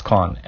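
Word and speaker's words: Schön: I think it's pronounced as Schoen, S-C-H-O-N.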